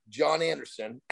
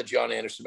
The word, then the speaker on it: Jon Andersen: Your goals are specific to you, but I want people go to my Instagram account, which is the Jon Andersen, at the Jon Andersen, but keep in mind, my name is spelled a little goofy. I'm Nordic. So J-O-N, which is not the Nordic part, and Andersen is A-N-D-E-R-S-E-N.